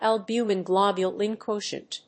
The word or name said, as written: albumin-globulin quotient